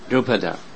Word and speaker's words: Drupada: Drupada.